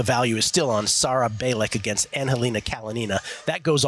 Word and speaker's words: Bejlek: So on the lady side, and this is in Austin, Texas, uh, I am on Sarah Bailey, uh, Balik. Let me tell you how that's spelled. It's spelled like Sarah Bejlek, but it's pronounced Sarah Balik.